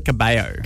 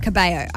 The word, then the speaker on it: Cabello: I say Camila Cabello. – Cabello. I've been saying it wrong this whole time.